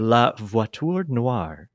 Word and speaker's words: La voiture noire: La voiture noire.